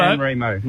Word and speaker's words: San Remo: How are we saying it properly? – Remo, righto. – San Remo. – San Remo, righto, mate.